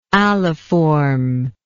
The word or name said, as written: aliform